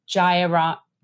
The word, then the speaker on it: Jayaraman: No, Jayaraman. No, that's not an H, it's a Y.